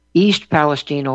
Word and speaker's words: East Palestine: Oh, East Palestine, and that's how they say it. East Palestine, Ohio.